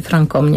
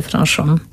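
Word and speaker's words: Franchomme: Pisze oczywiście do Francom, bo we Francji wymawia się Francom, nie Franchom.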